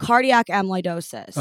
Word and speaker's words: cardiac amyloidosis: Cardiac amyloidosis.